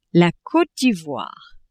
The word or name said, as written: Cote d'Ivoire